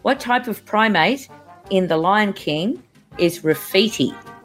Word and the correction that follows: Rafiki: Um, well, one correction, it's pronounced Rafiki with a K.